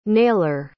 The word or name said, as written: naylor